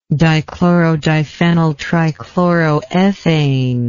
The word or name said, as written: dichlorodiphenyltrichloroethane